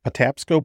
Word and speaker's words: Patapsco: Patapsco Park. Is mm-hmm. that how you pronounce it? – Yeah. – And you're like,